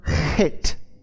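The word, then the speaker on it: het (חית): It's the word, it's pronounced het. Het.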